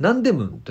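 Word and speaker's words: ナ ン デ ム ン (남대문): はい、はい、で 3 日目は、まあ、ホテル戻りまして今度はね、あのー、ナンデムンという、あの、左に大門じゃなくて、南大門と書いてナンデムンというところに、えー、行ってきてます。これ、あの、トンデムンのすぐ近くにあるんですけども、ナンデムン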